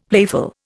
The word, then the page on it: playful: Indian